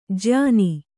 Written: ಜಯನ

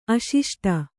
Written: ಅಶಿಷ್ಟ

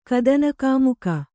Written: ಕದನಕಾಮುಕ

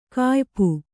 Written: ಕಾಯ್ಪು

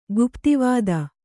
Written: ಗುಪ್ತಿವಾದ

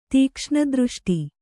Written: ತೀಕ್ಷ್ಣದೃಷ್ಟಿ

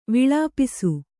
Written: ವಿಲೇಪಿಸು

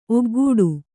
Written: ಒಗ್ಗೊಡೆ

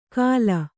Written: ಕಾಲ

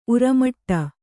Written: ಉರ್ಮಟ

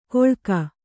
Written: ಕೊಳ್ಕ

